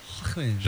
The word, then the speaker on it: Joaquin: Joaquin. – You gotta say the right. You can't say the right way. – Joaquin. – You're trying, but you're falling short a little. Joaquin.